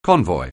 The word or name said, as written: convoy